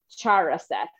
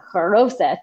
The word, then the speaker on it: haroset: However, it's not a charoset. – Right. – It's a haroset. It's not a charoset, Noel.